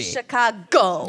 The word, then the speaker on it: Chicago: Chicago never met a G they didn't want to pronounce. – The bastardized Battaglia. They don't do the silent G. – Chicago. – Yeah. It's not Chicago.